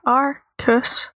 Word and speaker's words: Arceus: Arceus, okay.